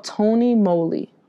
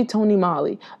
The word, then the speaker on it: Tony Moly: The brand is called Tony Moly or it could be Tony Molly.